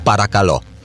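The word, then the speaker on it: parakalo (παρακαλώ): Evcharisto i parakalo, dziękuję, ale parakalo, proszę, proszę, proszę, cały czas parakalo. Tak akcentowane.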